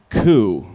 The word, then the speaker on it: QUE: So, by the way, you are mispronouncing this acronym. It actually, it sounds like coup. – So coup. Oh. – It's coup. – Q-U-E. – Yeah, Q-U-E.